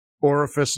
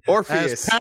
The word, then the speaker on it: Orpheus: Orifice 9. I thought there were only three. – Orpheus.